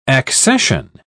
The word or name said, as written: accession